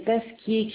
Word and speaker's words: Pasquier: Je suis Madame Pasquier Christiane, bâtiment arrivée à Léon-Richapelle.